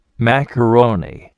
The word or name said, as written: macaroni